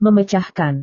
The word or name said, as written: memecahkan